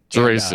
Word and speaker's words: Guarasci: Well, first and foremost, I had to correct your pronunciation. Patrick uh, Patrick Guarasci. – Guarasci, and, uh, thank you. I would love being Patrick corrected on the G.